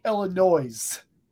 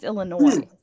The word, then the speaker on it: Illinois: You're all-time top-tier talent, but you say Illinois. – Oh, does he? – What's wrong with that? That's how I say it. – No, because the S is silent. It's Illinois.